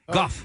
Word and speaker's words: Gogh: Vincent van Gogh. Gough. – Yeah, well, that's how you say it in French.